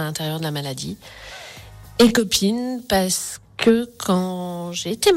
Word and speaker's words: Kopin: Alors, littéralement, Cop-In, il s'écrit K-O-P-I-N, parce que depuis plusieurs années, le K vient symboliser le cancer.